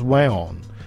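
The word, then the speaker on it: weón: And Weon, but in but pronounced Weon, is like the most used slang word in Chile, and it means a whole bunch of things.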